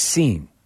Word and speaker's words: Sean: Went to college with a guy in Boston whose name was spelled S-E-A-N. But it was pronounced Scene.